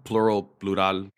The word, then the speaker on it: Plurawl: Now, I want to know about the story behind Plural. Did I pronounce it right? – Yeah, Plural, Plural.